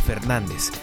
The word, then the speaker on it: FRDZ: Hoy nos toca hacer parada en tierras hidrocálidas para conocer a Fernández, escrito como FRDZ, pero que se pronuncia así meramente. Fernández.